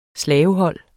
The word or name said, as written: slavehold